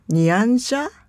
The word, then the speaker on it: Nyanja: You edited the dialogue in English, Nyanja, am I saying it right?